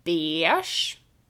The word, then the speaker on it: beige: Ordet är beige, alltså färgen som är en blandning av ljusgul och ljusrosa. Och det finns ett gäng. Ofta samma gäng som uttalar kex, kex som uttalar beige bash. Och jag förstår inte riktigt varför. Så, du får välja om du vill uttala det beige eller bash.